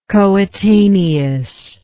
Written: coetaneousness

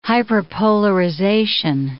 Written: hyperpolarization

